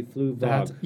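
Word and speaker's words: Fluevog: Fluvong. – Fluvog? – Fluvong. But I can imagine this. That's not an easy name to pronounce. – Did I put an N in there? There should be Fluvog.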